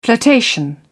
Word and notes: flirtation: British English

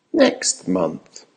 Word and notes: next month: American English

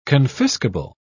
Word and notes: confiscable: British English

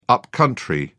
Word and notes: up-country: British English

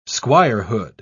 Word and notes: squirehood: British English

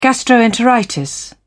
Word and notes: gastroenteritis: British English

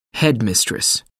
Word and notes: headmistress: American English

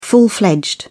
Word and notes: full-fledged: British English

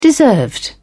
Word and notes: deserved: British English

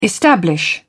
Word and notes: establish: British English

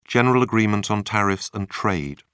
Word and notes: General Agreement on Tariffs and Trade: British English

